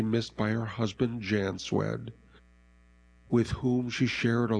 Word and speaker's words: Szwed: Carol Robertson Zwed. That's spelled S-Z-W-E-D. Carol Robertson Zwed, 75, of Palmer, passed away on April 19th.